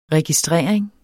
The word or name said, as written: registrering